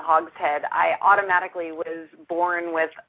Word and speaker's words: Hogshead: Am I pronouncing that right, Sally? – You are. It's Hogshead, like the head of a hog.